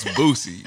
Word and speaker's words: Boosie: Bro, did you say Boozy or Boosie? – Boosie? What? How you pronounce it? – It's Boosie, bro.